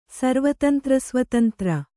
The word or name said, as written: ಸರ್ವತಂತ್ರಸ್ವತಂತ್ರ